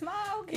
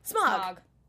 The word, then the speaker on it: Smaug: I love that they pronounced it that way instead of it being like Smaug. Smaug. And I was like, oh, my nerdiness, it feels good, guys. You're doing it right.